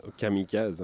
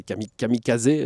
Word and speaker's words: Kamikaze: Kamikaze, hein, je pense que c'est Kamikaze. – Kamikaze. – Kaze. Kamikaze, ouais. Avec la prononciation japonaise.